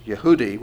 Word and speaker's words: Jehudi: And then it says, then all the officials sent Jehudi, actually probably pronounced Yehudi, which is actually where we get the word Judah.